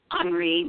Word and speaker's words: Henri: My name is Henri. It's French, it's pronounced Henri, but in English it's Henry.